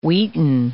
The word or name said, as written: wheaten